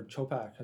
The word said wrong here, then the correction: Chopra: Is that how you say his name? – Chopra.